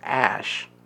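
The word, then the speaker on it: Æ: Baby X, and they sing the A E because the A and E are smushed together. It's pronounced Ash.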